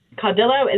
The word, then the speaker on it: Caudillo: I'm curious, am I pronouncing your name correctly? Is Caudillo the way you like to say it? – Caudillo is the way we say it, yes.